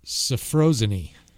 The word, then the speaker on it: sophrosyne: S-O-P-H-R-O-S-Y-N-E. Sophrosyne, or sophrosyne, if you prefer. – Sophrosyne? – It's another word, another way you can pronounce it. Sophrosyne or sophrosyne. – S-O-P-H-R-O-S-Y-N-E-S.